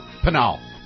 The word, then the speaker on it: Pinal: Pinal? – Pinal. – Oh. – Pinal County. – Penal County? – Not Penal County. I know. – It is for him. – Pinal County.